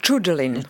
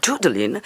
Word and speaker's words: Judeline: Et aujourd'hui, c'est pour la musicienne espagnole Judeline, je le dis bien. Vous voulez dire Rodeline, peut-être. – Je crois que c'est Judeline. – Judeline, et son nouveau single, Besito, qui vient de sortir.